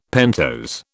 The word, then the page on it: pentose: British English